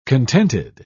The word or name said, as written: contented